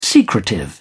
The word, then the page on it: secretive: British English